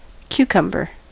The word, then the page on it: concombre: kɔ̃.kɔ̃bʁ; France